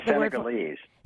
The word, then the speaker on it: Senegalese: It's pronounced Senin. Can you pronounce it? – Senegalese. – Senegalese.